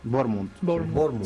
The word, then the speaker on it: Bournemouth: ¿Bournemouth se pronuncia? – Bournemouth.